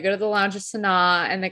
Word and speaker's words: Sanaa: Oh, I gotta go to Sanaa. Is that how you say it? – Oh, yeah, Sanaa. Yeah.